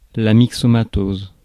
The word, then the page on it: myxomatose: /mi.ksɔ.ma.toz/